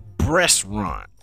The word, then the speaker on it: breastaurant: Did I pronounce it right? Yeah, it's called a breastaurant. Not a restaurant, a breastaurant.